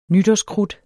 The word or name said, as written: nytårskrudt